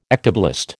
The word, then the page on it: ectoblast: American English